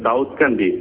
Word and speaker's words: দাউদকান্দি: Please please, এটা হচ্ছে দাউকান্দি সোনাতলি গ্রাম, right? – না স্যার, এটা হচ্ছে দাউদকান্দি,